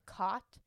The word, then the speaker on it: Cat: Her name is actually Cot, but it's spelled Cat.